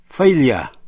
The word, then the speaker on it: failure: Cũng nhận xét thêm về cách phát âm, động từ to fail đọc với vần A, F-A-I-L, nhưng khi sang danh từ failure, F-A-I-L-U-R-E, đọc là failure. Fail, failure. Không đọc là failure.